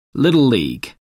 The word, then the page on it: little league: American English